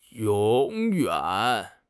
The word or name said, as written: yong3 yuan3